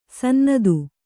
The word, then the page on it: ಸನ್ನತ: kannada